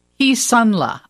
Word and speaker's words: Kisunla: I think this is how you say it. Kisunla. – Kisunla. Okay, Kisunla. Yes. It's K-I-S-U-N-L-A.